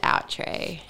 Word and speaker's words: Outré: But we've Australianized the name and we just pronounce it Outré.